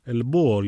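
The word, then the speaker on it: El Búho: Ελμπούο λοιπόν, γράφεται με H, δεν προφέρεται το H, όπως και στο επίθετο της Βάος, που είναι Βάος, αν θέλετε να τους αναζητήσετε.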